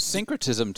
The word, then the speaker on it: syncretism: Syncretism too, right? – Yeah, yeah, absolutely.